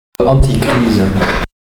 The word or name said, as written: anti crise